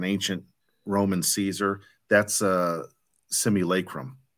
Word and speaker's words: Simulacrum: It's spelled S-I-M-U-L-A-C-R-U-M. Simulacrum.